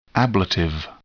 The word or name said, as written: ablative